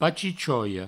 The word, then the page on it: pachichoia: pachichoio